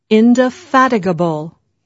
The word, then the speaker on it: indefatigable: Just remember that the stress is on the third syllable, indefatigable.